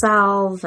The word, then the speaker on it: salve: The salve and yes, the salve. – Salve. I can never pronounce that correctly. – The silent L.